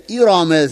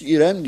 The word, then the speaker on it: İrem: Tabi burada biz tecvidle okurken Kur'an-ı Kerim'de hükmü ra dediğimiz R harfi fethalı olunca kadın okunuyor. Onun için mesela İrame zâtil imad. Ama biz bunu Türkçe'de telaffuz ederken İram demiyoruz, İrem diyoruz tabii.